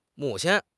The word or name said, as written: mu3 qin1